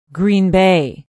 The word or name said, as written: green bay